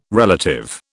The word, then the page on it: relative: British English